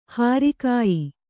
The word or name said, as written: ಹಾರಿಕಾಯಿ